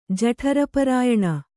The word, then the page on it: ಜಠರಪರಾಯಣ: Kannada